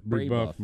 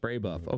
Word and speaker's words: Brebeuf: Fountain Central came up short against Brebeuf? Is that how you pronounce that? Brebeuf, Jesuit? Are you familiar with that? I'm getting the 9 mile stare. – Brebeuf, my friend. – Brebeuf, okay. – Brebeuf. – There we go.